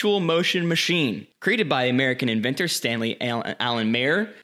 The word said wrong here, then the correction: Meyer: I think it's pronounced Meyer. – Meyer, thank you.